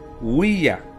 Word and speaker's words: Ouija: Según la grafía recomendada por el RAE, la Ouija es del francés oui, que significa sí, y del alemán ja, pronunciando Ouija.